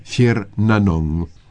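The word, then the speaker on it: Tír na nÓg: Ello se pronuncia Shir Nanong.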